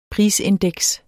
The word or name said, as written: prisindeks